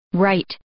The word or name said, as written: right